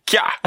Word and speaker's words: Kaya: Kaya Simon. – Ooh, Australia. – Mm, yes. Two for two. Kaya. It's spelled like kya.